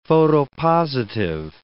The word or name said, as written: photopositive